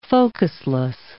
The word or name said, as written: focusless